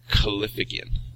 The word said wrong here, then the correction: callipygian: Caliphagian. There we go. – Spell that. – Maybe I'm mispronouncing it, but Callipygian.